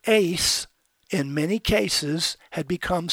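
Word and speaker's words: ace (εἰς): And the preposition ace, it's pronounced like it was A-C-E, but in Greek it's E-I-S, ace, meaning to or toward, to and touching.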